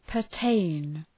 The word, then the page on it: pertain: pər’teın